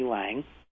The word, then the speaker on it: Laing: Lang, is, by the way, it's spelled L-A-I-N-G, and I think a lot of folks won't know that, R.D. Lang.